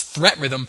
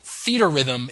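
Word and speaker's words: Theatrhythm: I thought this was Threat Rhythm, and then I'd heard someone pronounce it Feet Rhythm, which is actually how you'd think it was pronounced, but then I heard someone from Square pronounce it Theater Rhythm. It's supposed to be like Theater and Rhythm put into one word.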